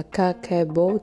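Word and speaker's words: Kakeibo: Kakaibo, phát âm là Kakaibo,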